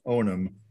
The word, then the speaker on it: Onam: Oh, no, it's spelled O N A M Onum Onum.